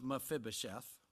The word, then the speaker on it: Mephibosheth: And this servant of Mephibosheth, I want to say it a lot because I learned how to pronounce it.